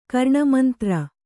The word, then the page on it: ಕರ್ಣಮಂತ್ರ: Kannada